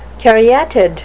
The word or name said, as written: caryatid